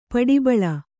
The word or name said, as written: ಪುಟಬಾಳೆ